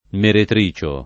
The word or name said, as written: meretricio